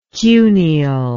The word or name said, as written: cuneal